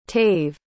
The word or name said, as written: tave